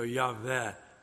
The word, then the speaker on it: Yahweh: because there is no W sound in spoken Hebrew. It's a V. So Yahweh would be closer to it.